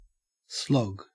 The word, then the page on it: slog: Australian English